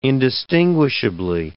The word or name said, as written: indistinguishably